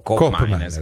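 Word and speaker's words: Koopmeiners: Qui parliamo fiammingo, che è l'olandese, per la cronaca colgo l'occasione per dire che di Cop Miners, la doppia O di Cop Miners invece si legge U. – Oh. – No, no, O si legge O, invece OE si legge U. Quindi Cop Miners è corretto chiamarlo Cop Miners.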